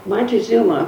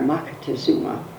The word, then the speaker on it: Moctezuma: Montezuma, whose name wasn't really Moctezuma, we say that because that's the way the conquistadores pronounced it. But there is an H and a K in there. Moctezuma was his name.